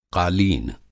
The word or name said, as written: क़ालीन